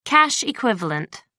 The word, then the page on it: cash equivalent: American English